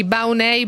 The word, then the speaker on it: Baunei: Salvatore Corrias, il sindaco di Baunei, buongiorno. Ho messo bene l'accento, sindaco? – Benissimo, buongiorno a voi e a chi ci ascolta.